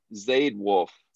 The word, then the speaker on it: Zayde Wølf: And it's actually pronounced just Zayde Wølf.